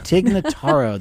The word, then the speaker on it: Tig Notaro: Tig Notaro.